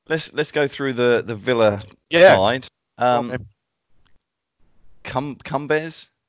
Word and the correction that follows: Coombs: It was pronounced Coombs, not Cumbers.